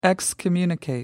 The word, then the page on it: excommunicate: American English